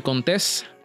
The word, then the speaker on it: Contés: Y mi nombre es Miguel Contés, con acento en la E, y nos vemos en la próxima.